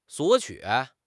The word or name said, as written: 索取